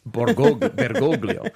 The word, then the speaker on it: Bergoglio: Borgoglio. Yeah. – You want to try that again? – Bergoglio.